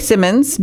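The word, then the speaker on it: Simonds: Simmons, but Simmons is spelled S-I-M-O-N-D, D as in dog, S. So Gary R. S-I-M-O-N-D-S .com.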